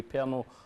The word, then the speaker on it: Pernod: It's aniseed, and it would be called pernod here, but it's silent D, pernil or Ricard.